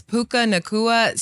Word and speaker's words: Puka Nacua: Yeah, it's Puka Nacua.